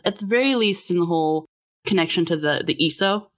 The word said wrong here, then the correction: Isu: Is that how it's pronounced? – Isu.